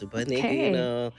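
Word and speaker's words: Upa Neguinho: My Portuguese is not so great, but I think that's pronounced Upa Neguino. – Yes, Upa Neguino.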